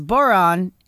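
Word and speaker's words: bodhrán: Yes, I was just want to point out that Ken Larson's signature on his email says, Boron, it rhymes with moron.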